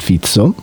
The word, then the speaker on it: Fico: E in particolare c'è un personaggio di cui abbiamo già parlato e che per la prima volta pronunceremo in slovacco, che abbiamo sempre pronunciato in italiano. – Cosa che mi dispiace anche un po'. – Esatto, è Robert Fizzo, che si scrive Robert Fico,